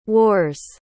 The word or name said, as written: war's